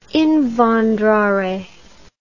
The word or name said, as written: invandrare